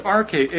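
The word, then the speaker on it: arche (ἀρχή): The word chief there is arche, A-R-C-H, long E, and it means a ruler or one in government.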